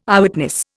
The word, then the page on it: Eyewitness: Indian